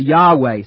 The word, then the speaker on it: Yahweh: Now, it's Yahweh seems to be a better pronunciation.